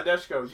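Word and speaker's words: Nadeshiko: Nadashiko? – Nadeshko. – You will never pronounce that right on the first try.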